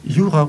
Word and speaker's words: Jura: Jura ударение на первый слог.